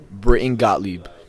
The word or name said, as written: Brittain Gottlieb